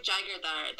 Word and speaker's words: Jaigirdar: By the way, you say Jaigirdar. That's how you pronounce it.